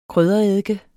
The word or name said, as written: kryddereddike